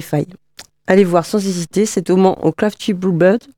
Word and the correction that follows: Crafty Brew Pub: Je ne sais pas si je prononce bien. Hein. – Crafty Brew Pub. – Pub, yes. Voilà, merci.